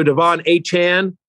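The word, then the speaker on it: Achane: So Devon Achan, that's how he likes his name to be pronounced.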